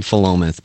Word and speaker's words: Philomath: It's called Philomath, but we call it Full of Meth.